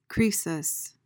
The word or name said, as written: croesus